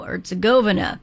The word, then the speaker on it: Herzegovina: I keep saying it wrong. The emphasis is on the go, Herzegovina.